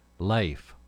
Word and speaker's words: Leif: It's pronounced Leif, by the way, like safe.